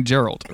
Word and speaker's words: Geralt: It's definitely Geralt, right? That's you go with the soft G.